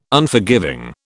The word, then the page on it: Unforgiving: British English